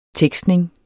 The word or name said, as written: tekstning